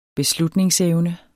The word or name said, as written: beslutningsevne